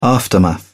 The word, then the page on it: aftermath: British English